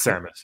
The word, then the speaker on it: Samus: It's Samus.